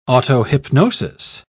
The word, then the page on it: autohypnosis: British English